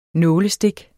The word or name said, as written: nålestik